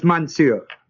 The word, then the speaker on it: monsieur: In French, the same meaning as monsieur.